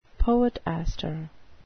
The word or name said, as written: poetaster